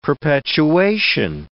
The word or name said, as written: perpetuation